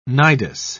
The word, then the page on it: nidus: British English